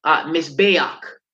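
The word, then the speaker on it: Mizbeach (מזבח): So when we say altar, the Hebrew word for altar is Mizbeach. It's pronounced Mizbeach.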